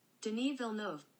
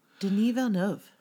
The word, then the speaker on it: Denis Villeneuve: Denis Villeneuve. – Thank Villeneuve. You. Thank you, Google. – Denis Villeneuve. – Prisoners, directed by Denis Villeneuve. – Denis Villeneuve.